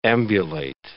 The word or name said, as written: ambulate